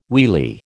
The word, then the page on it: wheelie: American English